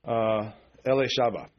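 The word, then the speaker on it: Elisheba: And in Hebrew, you'd pronounce it Elishaba. That's Elizabeth.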